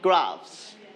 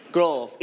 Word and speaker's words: graphs: This one, it sounds like graphs. – Graphs. – Graphs. But you seems like growth. It's not growth. It's graphs.